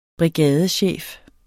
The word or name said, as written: brigadechef